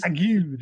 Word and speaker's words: ague: I mean, that what was that? Agrue? Was that what a fever is? – Ague. The ague.